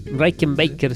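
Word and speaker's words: Rickenbacker: No sé si lo pronuncio bien, el Rakenbaker Rakenbaker, sí, sí, sí.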